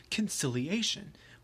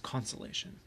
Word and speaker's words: conciliation: The federal government's policy regarding the seceded states was heretofore known as conciliation, which I previously was mispronouncing as consolation.